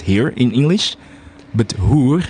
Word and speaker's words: hoer: And let's say you have H-E-E-R. It's pronounced here. But when you say H-O-E-R, it's hoer.